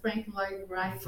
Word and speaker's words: Frank Lloyd Wright: Adoro Calatrava, adoro Antônio Gaudí, Frank Lloyd Wright. É, falei certo? – Frank Lloyd Wright.